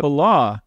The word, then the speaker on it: Fallow: I think I said your last name wrong. – That's okay. It's very hard. I went from Stanley to Falah, so no problem. – Yep. Falah.